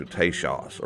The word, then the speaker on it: Tejas: Probably the pronunciation was closer to Tejas or Tachos than to Texas.